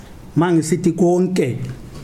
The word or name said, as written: hu-n-ye